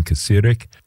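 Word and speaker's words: Kacirek: It's a duo consisting of German electronic experimental drummer Sven Kasirek. That's K-A-C-I-R-E-K, Sven Kasirek, and singer-songwriter and instrument maker Olith Rategó, R-A-T-E-G-O.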